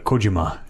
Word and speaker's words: Kojima: You have to put the emphasis on the Kojima if you're going to say it like one of us.